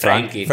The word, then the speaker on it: Frankie: Frankie. Well, but Frankie. It's more American word anyway.